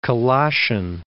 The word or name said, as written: Colossian